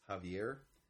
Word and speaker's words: Javier: being a Spanish speaker, the way you say it in Spanish, it's often spelled with a J when people write it nowadays. It's Javier. So that's how you would pronounce it if it's a span, like Don Quixote, where you have an X, but it's pronounced like an H or a J.